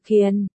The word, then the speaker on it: Càn: Hay vãi, Ngô Hữu Càn Ngô Hữu Kiền không có tiền, Càn phát âm là Kiền.